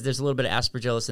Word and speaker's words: Aspergillus: this has some asparagus in it. I'm saying it wrong. But how do you say it? – Aspergillus. – Aspergillus, exactly. I knew it. I could see it in my head. Aspergillus.